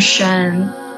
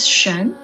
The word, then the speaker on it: Xuan: I'm Xuan. Nice to meet you. Yeah, it's always awkward to start with that. My name is Xuan. It's spelled as X-U-A-N.